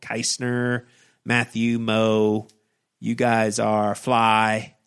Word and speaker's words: Meaux: How would you pronounce M-E-A-U-X? Is that Moe? – Moe.